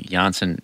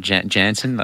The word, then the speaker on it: Janssen: Did you hear we've been pronouncing Janssen incorrectly, Dad? – Soft J. – It's not Janssen.